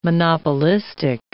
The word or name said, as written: monopolistic